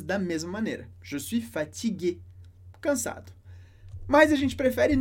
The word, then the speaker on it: fatigué: Se fosse uma moça, je ne suis pas fatigué. Pronuncia da mesma maneira com um E no final.